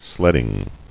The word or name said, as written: Sledding